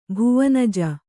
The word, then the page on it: ಭುವನಜ: Kannada